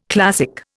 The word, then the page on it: classic: Indian